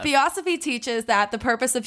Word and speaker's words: Theosophy: That's how you say it. Not Theosophy. It's Theosophy.